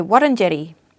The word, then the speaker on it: Wurundjeri: Well, I have to stop myself, because I've been saying Wurundjeri this whole time. I've got to say Wurundjeri. Aunty Di, I work closely with her in a lot of different things, or she's at same events as me.